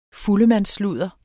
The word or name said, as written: fuldemandssludder